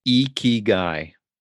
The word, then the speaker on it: ikigai: Ikigai.